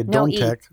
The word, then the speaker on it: Dometec: We say Dome Tech, spelled like Dom Tech.